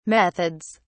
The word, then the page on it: methods: British English